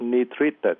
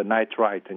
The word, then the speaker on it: nitrite: Thì lý do trong hai em bé này là chúng nó bị cái gọi là ngộ độc một cái chất nitrite, tiếng Anh đọc là nitrite nhưng mà chúng ta đọc cái dễ là nitrite, N-I-T-R-I-T-E. Sau khi